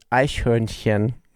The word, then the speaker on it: Eichhörnchen: My fascination with expats striving to master German, especially tricky words like Eichhörnchen.